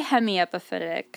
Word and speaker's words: hemiepiphytic: I would say hemiepiphytic. – Yeah, that sounds right.